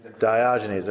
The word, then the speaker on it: Diogenes: Am I saying that right? Diogenes, there we go.